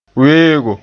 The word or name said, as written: weego